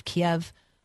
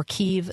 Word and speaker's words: Kyiv: Missiles struck the capital city of Kiev or Kyiv this morning, and both pronunciations are just fine.